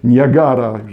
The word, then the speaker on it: Niagara: U mnie Najga, Niagara, już zostawię tą wymowę, e, Niagara jest największy z wodospadów i gwiazd 50 moja flaga ma.